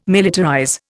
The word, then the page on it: militarize: Indian